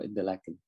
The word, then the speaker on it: Interlaken: Đọc từ tiếng Mỹ Interlake, mà bên đây đọc là Interlaken, tức là liên hồ, một cái hồ Thun và một cái hồ Prien.